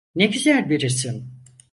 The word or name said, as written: isim